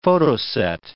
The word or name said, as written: photoset